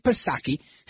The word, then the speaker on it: Psaki: Now, Peter Ducey is at a press briefing with Jen Psaki, silent P, of course.